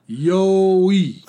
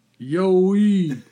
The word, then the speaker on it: Yowie: My kids and I were laughing out loud when was reading the letter from a listener that described the YouTuber that pronounced the name of our beloved cryptid, Yowie, more like Rocky Balboa would speak to Adrian, like, Yowie. Hey, I just saw a Yowie."